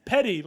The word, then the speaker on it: Petty: You said it wrong like three times. – Petty, like Bryce Petty, or Tom Petty.